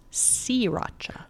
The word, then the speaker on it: Sriracha: It's actually pronounced Sriracha. – Sriracha. Guess what? No one's going to do that. Neither am I. – Kind of like, it's not a Beamer, it's a Bimmer. – Right. – If you own a BMW. – We're still going to say Sriracha, even though there's an R in there that you don't pronounce, because it's S-R-I-R-A-C-H-A.